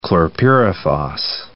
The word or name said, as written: chlorpyrifos